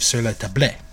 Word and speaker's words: Sur la Table: So he goes to Sur la Table, or Sur la Table, but it's pronounced, I believe, Sur la Table.